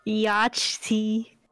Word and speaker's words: Yachty: No, that's not how you say his name. – Say Yachty.